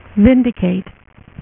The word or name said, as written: Vindicator